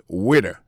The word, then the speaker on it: waiter: Alors moi, je prononce waiter. Waiter, ça veut dire accent tombé sous le premier syllabe là. Waiter. W-A-I-T-E-R.